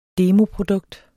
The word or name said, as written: demoprodukt